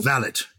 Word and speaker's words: valet: No, and we'll come on to the valet, which is correctly pronounced valet, in my view.